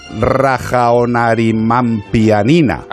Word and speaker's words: Rajaonarimampianina: Rajaonarimampianina. – Ah, pues sí, le ha dado una sílaba más que yo me había comido.